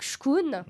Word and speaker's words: chkoun: Voilà, c'est qui est là, donc « Shkun ». Donc ça s'écrit C-H-K-O-U-N.